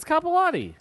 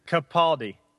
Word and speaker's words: Capaldi: Wow, dude, that's two names you've butchered. – It is. It's Capaldi. – I thought it was going to be Capaldi. – Matt Damon. – Capaldi.